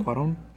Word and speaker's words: Cuarón: In order to acquaint himself with his three lead actors, director Alfonso Cuarón. Sure. Alfonso. – I actually don't know. I didn't read it. – And Cuarón It's with an N at the end. So, yeah. Cuaron. – Oh, Cuaron, yeah.